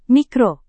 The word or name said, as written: mikro